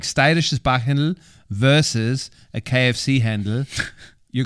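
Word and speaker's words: steirisches Backhendl: Sag mal dreimal steirisches Bachhändl. – Steirisches Bachhändl, steirisches Bachhändl, steirisches Bachhändl. What? I say it like a local, no? – Yeah.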